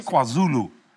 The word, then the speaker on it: KwaZulu: No, Captain, that's KwaZulu.